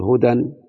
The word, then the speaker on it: هدى: قد يقول قائل ما الألف موجودة؟ أقول هي موجودة رسما لا نطقا، فالعبرة بالنطق. أقول هدى ورحا ومسعا ومرما ومفتدا ومستشفا، كل هذه الأسماء التي قلتها الآن يعني الألف حذفت وبقي التنوين لالتقاء الساكنين، بس هي بترسم يعني.